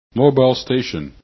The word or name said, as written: MS